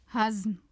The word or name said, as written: hazm